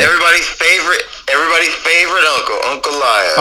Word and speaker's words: Ankalaev: This is the easiest way to learn how to say his last name. Okay. Uncle Lyov. – Okay, Magomed Uncle Lyov. – But then you take the U and put an A.